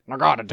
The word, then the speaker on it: Naganadel: Naganadel.